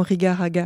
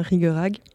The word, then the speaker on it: Riga-Raga: Alors moi j'aurais prononcé bêtement Rigaraga parce que ça s'écrit comme ça, mais tu viens de me dire qu'on prononce bien Rigorag.